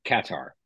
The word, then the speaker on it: Qatar: And instead, I ended up going from Jayburg to Qatar, which I've been saying it wrong this whole time. Like Qatar Airlines. – Everyone usually says Qatar. – Or Qatar, right? – Yep. – So Qatar Airlines says Qatar like that. And I'm like, okay, well, I guess you guys probably know how to pronounce it.